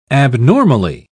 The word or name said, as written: abnormally